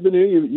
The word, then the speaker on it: Bennu: So the asteroid Bennu, you pronounced that correctly.